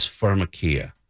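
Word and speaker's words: pharmakeia: Your body is, you know, what's interesting is the word for sorcery that's translated into sorcery in scripture from the Greek is pharmakia. Now, yeah. Pharmakia sounds like the word pharmacy.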